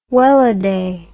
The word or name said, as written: welladay